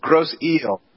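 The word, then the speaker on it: Grosse Ile: But apparently it's not. It's Gross I-L-E, eel, like short for an island, but that's what they pronounce it as eel. Yeah. – I thought it was I-S-L-E-T, so I'm like, what? – Islet.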